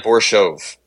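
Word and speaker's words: Borshchev: How do you say this gentleman's name? Vyacheslav Borishev? – It sounds like Borishov.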